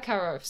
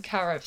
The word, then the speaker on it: Caroff: So it's Maeva Karoff. So it's like M-A-E-V-A and then Karoff, C-A-R-O-F-F.